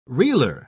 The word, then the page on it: reeler: British English